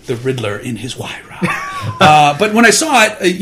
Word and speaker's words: Huayra: Is that how you pronounce it? – Yeah, that is how you pronounce it. I said it correct. – Say it. – Huayra. – Nice. – Huayra.